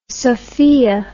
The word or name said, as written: sophia